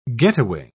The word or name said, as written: getaway